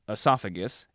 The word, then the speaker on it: esophagus: So the in esophagus is an open vowel. That open vowel is followed by the stressed syllable sa, resulting in esophagus instead of esophagus.